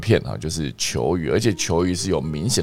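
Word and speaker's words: 犰狳: OK，好有趣哦，我今天长知识了，不然之前都不知道球鱼要怎么念哦，一个犬字边一个九，一个犬字边一个雨天的雨，哈，它叫做球鱼哈，球鱼可以缩成一。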